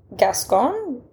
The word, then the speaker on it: Gascon: I am drinking a lovely French Gascon. – Mm-hmm.